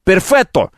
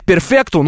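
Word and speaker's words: perfetto: Перфетто. Кто-то говорит перфекто, но он урод, потому что правильно говорит перфетто.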